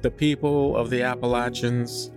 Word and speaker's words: Appalachian: It's pronounced Appalachian. And if you don't say it like that, they will string you up and beat you to death. They are very, very picky about the way you say that mountain range, apparently.